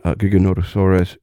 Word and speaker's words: Giganotosaurus: I want to ask you more specifically about Giganotosaurus in a moment.